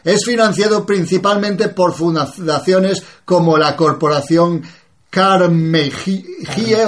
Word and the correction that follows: Carnegie: o como se pronuncia, es, me imagino que es carmesí en otro idioma, o sea, la moradita o algo así. – Es Carnegie, es un banquero de la vieja guardia.